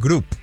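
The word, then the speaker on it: Grupe: Ya lo contrataron y viene de los New York Jets, procedente del equipo de prácticas, Blake Groupe. No sé si se pronuncia así o es Blake Group.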